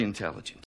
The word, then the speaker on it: intelligent: I'm very intelligent. – She's very intelligent. – Intelligent. Inteligente. Ok, pero ojo con la acentuación acá. Intelligent. Queremos enfatizar la segunda sílaba.